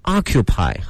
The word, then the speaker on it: occupy: P-Y, 发音是 occupy, 好，两个发音，美式发音的这个 O 呢会张得大一些，如果大家去美国的话要熟悉它的 O 变成 R 的那个感觉。